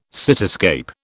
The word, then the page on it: cityscape: British English